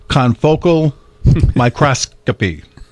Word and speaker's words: confocal microscopy: Confocal microscopy.